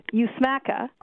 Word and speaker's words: USMCA: Well, first I'll help you with how to pronounce it. It's USMCA.